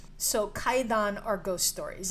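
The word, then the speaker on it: kaidan (怪談): It is pronounced kaidan.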